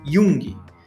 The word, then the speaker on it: Jung: Fica escrito Jung, né? Só que a gente pronuncia Jung.